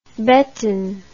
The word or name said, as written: beton